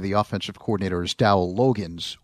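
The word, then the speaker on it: Loggains: I usually just call him Dow, but from what I can understand, it's Logans, like Kenny Logans.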